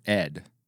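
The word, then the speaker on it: ed (אֵד): And the water in Hebrew, you pronounce it ed, but it's the same letters that you and I would transliterate as ad, mm-hmm.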